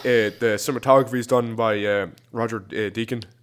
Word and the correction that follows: Deakins: Uh, Deakins. De- yeah, that, yeah, that's who pronounced it. Yeah, Roger Deakins.